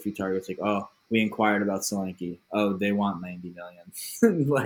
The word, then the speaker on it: Solanke: Solanke.